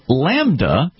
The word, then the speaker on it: LaMDA: Introduced in 2021, Google's Lambda, which is spelled L-A-M-D-A, Language Model for Dialogue Applications, is a system that consumes trillions of words from all corners of the Internet.